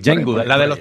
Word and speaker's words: Jane Goodall: El año pasado falleció Jane Goodall. Más o menos se pronuncia así, Jane Goodall, la de los chimpancéses.